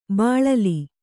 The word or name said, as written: ಬೀಳಿಲು